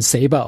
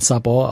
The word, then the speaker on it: Sabor: Ich habe gelernt, dass man das nicht, also im Englischen, die Amerikaner sprechen Saber aus, aber äh, waren viele spanischsprachige äh, Crewmitglieder auch an Bord und die sagen, also eigentlich spricht man Sabor aus.